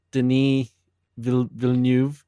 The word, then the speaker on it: Denis Villeneuve: Yeah, so this episode, we are going to discuss the 2016 film Arrival, directed by Denis Villeneuve.